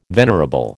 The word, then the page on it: venerable: American English